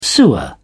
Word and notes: sewer: British English

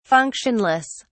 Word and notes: functionless: British English

